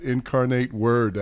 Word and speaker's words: Incarnate Word: And how about Cole Wick out of Incarnate Word, is that how you say it? Incarnate Word out of Texas.